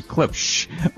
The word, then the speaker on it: Klipsch: K-L-I-P. Klipsch. Klipsch. Klipsch. Klipsch. Okay, Klipsch.